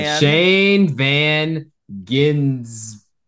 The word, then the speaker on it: Shane van Gisbergen: Shane Van Gisbergen.